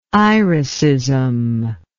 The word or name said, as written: iricism